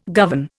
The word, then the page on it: govern: Indian